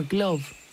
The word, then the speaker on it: gleow: A gleove is an ancient musical instrument in the Anglo-Saxon language, spelt G-L-E-O-W, or glove in its proper Germanic pronunciation.